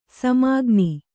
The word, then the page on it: ಸಾಮಗಾನ: sāma gāna